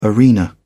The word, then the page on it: arena: British English